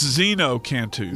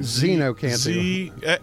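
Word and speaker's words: xeno-canto: It's ZenoCanto. – ZenoCanto.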